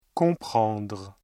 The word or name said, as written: comprendre